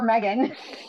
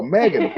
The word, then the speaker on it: M3GAN: And this is kind of an impromptu review reaction to, I'm not sure how to say the name of this movie, M3GAN? M3GAN? – Or Megan. – Oh, I totally didn't even get that. Oh, Megan, okay.